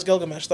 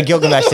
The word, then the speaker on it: Gilgamesh: That is how you pronounce Gilgamesh, though. – Yeah, Gilgamesh.